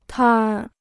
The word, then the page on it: 摊儿: tān r